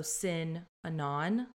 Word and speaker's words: Synanon: And it's spelled SYNANON, so Sin Anon.